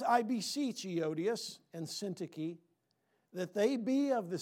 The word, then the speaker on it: Syntyche: In verse 2, I beseech Euodias, and it's pronounced Syntyche. I know it doesn't look like that, but that's how it's actually pronounced. I know we've had a lot of babies born in the church.